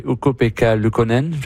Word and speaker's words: Ukko-Pekka Luukkonen: Newhook, hier, a déjoué Ukko-Pekka Luukkonen.